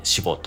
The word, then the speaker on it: 私募: えー、死亡者祭ですね、えっと、私に募ると書いて死亡と読み